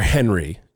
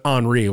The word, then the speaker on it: Henri: We just had Hurricane Henri, or Henry, as the layman calls it. But Henri was the way to pronounce it.